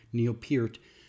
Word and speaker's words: Peart: Uh, trying to say make sure I say his name right. Neil Pert, Peart, Neil Peart, um, who passed away recently, he was the drummer and lyricist for Rush.